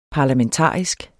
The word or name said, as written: parlamentarisk